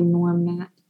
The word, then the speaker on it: Gnorm Nat: And his first comic on his own was called Norm Nat or Gnorm Nat. It was like Norm with a G. – Right. Norm. I bet that's a play on the way that Nat is spelled. So I think you're right. It's probably Norm Nat.